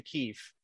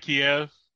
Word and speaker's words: Kyiv: It's not Kiev, like that's everybody how, else that, does it? – That's how the Russians pronounce it.